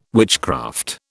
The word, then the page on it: witchcraft: British English